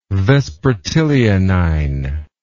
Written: vespertilionine

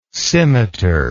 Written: simitar

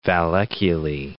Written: valleculae

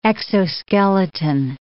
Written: exoskeleton

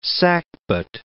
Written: sackbut

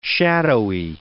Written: shadowy

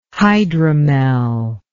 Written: hydromel

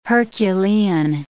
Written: Herculean

